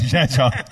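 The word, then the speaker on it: Rzeszów: Zeshov. Not even close. Zeshov.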